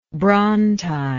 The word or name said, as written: brontide